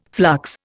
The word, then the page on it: flux: Indian